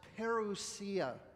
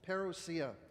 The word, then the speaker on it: parousia: The coming of Jesus, or better said, the second coming of Jesus, is encapsulated in a Greek word called, pronounced parousia, parousia.